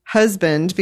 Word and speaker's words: Huzband: I thought I would read to you the very front, husband, because the book is spelled H-U-Z-B-A-N-D, pronounced husband, noun, one, a male partner in a marriage.